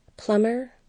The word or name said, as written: plumbing